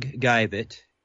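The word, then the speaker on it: Geivett: My website is a WordPress blog website at Doug Guyvett. My last name is spelled G like George, E-I-V-E-T-T.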